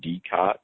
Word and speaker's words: Decot: In the prescription realm, I generally recommend the DECOT, D-E-C-O-T. It's pronounced either DECOT or DECO.